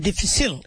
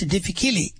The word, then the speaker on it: difficile: Everybody mispronounces it, call it difficile. It's not difficile, it's difficile.